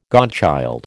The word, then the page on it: godchild: American English